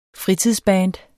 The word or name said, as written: fritidsband